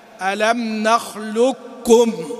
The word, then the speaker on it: ألم نخلقكم: فالإدغام الكامل يعني تنطق بلام بعدها كاف مشددة، ألم نخلقكم، ألم نخلقكم، لكم، ألم نخلقكم، واضح؟ وهذا في وجه آخر أنك تنطق زي ما قلت بسطة، ها نفس الشيء، يعني تطلع للقاف بس بدون ما تنطقها وبعدين تنزل على الكاف، ألم نخلقكم، نخلقكم، نخلقكم.